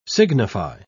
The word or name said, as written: signify